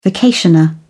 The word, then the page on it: vacationer: British English